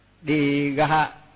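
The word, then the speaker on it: dīgha: ví dụ tới nó đi ga, tức là nó nhân, cho dù cho nhân nhưng mà mình vẫn đọc là đi ga thì nó mới đúng.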